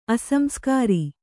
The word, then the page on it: ಅಸಂಸ್ಕಾರಿ: asamskāri